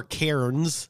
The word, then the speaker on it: Cairns: So a lot of people outside of Australia may have heard of Cairns. So Cairns is... – Or Cairns. – Yeah. – As it's spelt. – It's spelt C-A-I-R-N-S, but yeah.